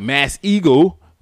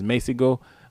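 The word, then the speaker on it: Masego: Shout out to Jasmine Blue for correcting my pronunciation of that. I talked about him, was it last week? Maybe week before. Yeah, Messego. I was like, Mass Eagle, but it's Messego.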